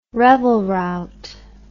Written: revelrout

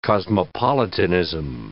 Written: cosmopolitanism

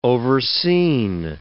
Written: overseen

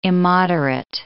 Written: immoderate

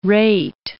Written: rate

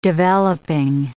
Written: developing

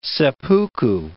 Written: seppuku

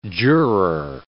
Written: juror

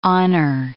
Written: honour

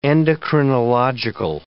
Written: endocrinological

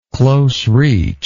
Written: close reach

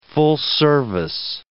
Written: full-service

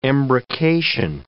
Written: embrocation

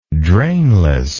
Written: drainless

